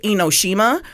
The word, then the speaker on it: Enoshima: And I will say, if you don't understand what CMC Howell said, I pronounce it Enoshima. – Yeah. – So that or Enoshima is the same.